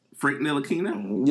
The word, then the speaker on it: Ntilikina: Frank Ntilikina? – Yep, that.